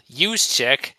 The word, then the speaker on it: Juszczyk: Yes, and by the way, it is a tough name to remember, but the name, of course, is actually pronounced Kyle Juszczyk, and I got to mention that he was a Cloverleaf product from Medina County.